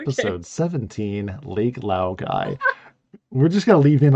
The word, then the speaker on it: Laogai: Laogai. – Wow, guys. – Laogai.